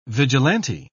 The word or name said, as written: vigilante